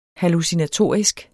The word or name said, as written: hallucinatorisk